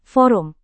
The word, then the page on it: forum: indonesian